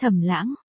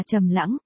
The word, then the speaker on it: Thẩm Lãng: Người thứ 10 từ dưới lên, ngón tay cô dừng một chút, S Thẩm Lãng, Thẩm là sen, thật ra tên Thẩm Lãng còn có thể được đọc là Trầm Lãng, nhưng nghe thế buồn quá nên mình không đổi.